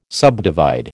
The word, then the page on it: subdivide: American English